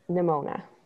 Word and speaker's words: Nimona: Pronounce that right? Nanoma? – Nimona. – Yeah, that's how it's spelled. – Ramona. Kind of rhymes with Ramona.